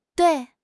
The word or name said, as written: dui4